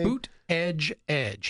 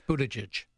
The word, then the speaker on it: Buttigieg: And he also said that comments over here from the likes of Bobo, commonly known as Beto O'Rourke, and how do you pronounce Booty, what's his name? – Boot Edge Edge. He has T-shirts he's selling on his campaign website. Boot Edge Edge. Buttigieg.